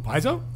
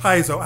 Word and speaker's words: Paizo: Who, Paizo? – Thank you. – Oh. – Yeah. Paizo.